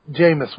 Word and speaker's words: Jameis: So I don't know anything about college football, but I keep hearing about this Jermaine Winston fella. Did I say his name right? Because I don't know about college football. – It's Jameis? – That's close. Jameis Winston.